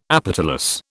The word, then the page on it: apetalous: British English